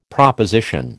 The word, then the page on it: proposition: American English